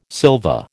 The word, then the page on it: silva: American English